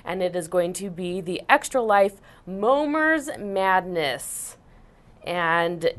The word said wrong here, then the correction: Momir: What's a Momer? – Momir. – Oh, I accidentally said it wrong.